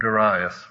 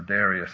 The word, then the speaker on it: Darius: So the work stopped for a period of 16 years until the second reign of Darius, or Darius, I'll pronounce it both ways.